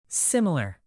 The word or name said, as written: similar